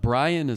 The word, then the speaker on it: Brion: Brian is spelled B-R-I-O-N. Last name Crum, C-R-U-M. So you can just type Brian Crum into a search engine and I'll pop up on LinkedIn and Facebook.